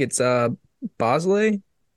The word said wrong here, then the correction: Bazley: Bosley? – Probably said that wrong. – Bazley. There you go.